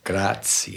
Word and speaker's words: Graz: Now, you're from, see if I could pronounce this properly, Graz. – Graz.